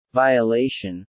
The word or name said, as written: Violation